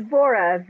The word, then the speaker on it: Vora: So dear Tiffany, which is Dr. Tiffany Vera for most of us. I'm pronouncing it correctly. Yes. – It's Vora, V-O-R-A. – Oh, I'm sorry. I keep doing this, even though it's written Vora.